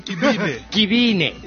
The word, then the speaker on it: Kibini: Did I say it wrong? – Kibine. – Yeah. There you go.